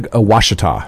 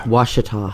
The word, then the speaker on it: Ouachita: Ouachita. – Ouachita. – Yeah. – Ouachita. – It is a French spelling of an Indian name. When you have an O and a U, makes the W sound.